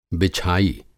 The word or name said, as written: बिछाई